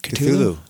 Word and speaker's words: Cthulhu: Cthulhu. Am I saying that right? – Cthulhu. – Cthulhu. Cthulhu. Not Cthulhu.